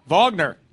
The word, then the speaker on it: Wagner: Wagner? – Yeah, it says Wagner for some reason. Okay. Oh, that's how they say it? – Yeah. – Oh, they say it with a V. Sorry. Okay. Wagner.